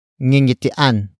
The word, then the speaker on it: ngingitian: Ngingitian is spelled as ng. I. Ng. I. T. I. A. N.